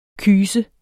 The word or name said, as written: KYSERNES